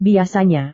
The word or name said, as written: biasanya